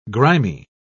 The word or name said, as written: grimy